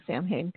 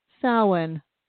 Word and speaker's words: Samhain: We should stop saying Samhain because that's going to really tick someone off. It's pronounced Samhain.